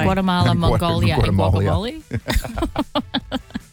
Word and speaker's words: Guatemala: Guatemala.